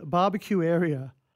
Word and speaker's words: Babakiueria: or a movie called Barbecue Area. Now, it's spelled B-A-B-A-K-I-U-E-R-I-A.